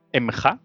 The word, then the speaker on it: Mga: Primero capturan la ciudad de Emja, o sea, yo creo que, bueno, se escribe MGA, yo creo que se pronuncia Emja o algo así, pero digamos que estos nombres rusos son un poco raros.